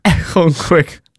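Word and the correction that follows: Eoghan: It's Owen. – Owen Quig. – It's just an Irish spelling.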